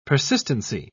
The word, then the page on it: persistency: British English